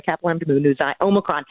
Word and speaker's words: Omicron: Omicron instead of Omicron? – I looked it up. – When There's this thing called Google, and I looked up the pronunciation of the Greek alphabet. Yeah, I think it's Omicron.